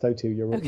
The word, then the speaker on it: O2: Is that pronounced O2? – Yes, they're pronounced O2. You have it right.